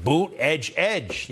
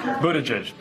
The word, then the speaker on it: Buttigieg: So the way to say Buttigieg, I didn't know at first, did you? Well, they made a big deal out of it. Boot, edge, edge. Yeah, that's how you say it.